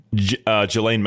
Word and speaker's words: Ghislaine: Ghislaine Maxwell. – Ghislaine. It's not spelt like that, is it? It's spelt very strangely. – S is silent, I believe. It's French.